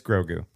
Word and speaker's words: Grogu: It's Grogu. – Grogu? – Okay.